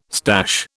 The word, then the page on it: stash: British English